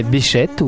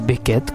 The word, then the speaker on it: Bechet: Les plus connus, citons en vrac Dizzy Gillespie, Duke Ellington, Claude Luther, Count Basie, mais aussi Sidney Bechet ou Beckett, comme vous préférez, puisque les deux prononciations sont possibles, que l'on entend actuellement derrière nous.